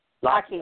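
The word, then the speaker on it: Lochte: Lockie.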